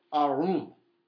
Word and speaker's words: arum: There's that Hebrew word, and it's we would pronounce it arum.